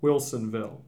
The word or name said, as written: Wilsonville